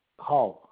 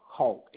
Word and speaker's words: Hull: Hull. Hull. H-U-L-L.